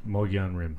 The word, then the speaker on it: Mogollon: Mogollon Rim.